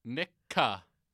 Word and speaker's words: Nikka: Nikka. – It's from Yoichi. – N-I-K-K-A.